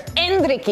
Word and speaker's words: Endrick: Well, 17-year-old Brazilian striker Endrick, did I say it right? – You got it. – Yes, I got it.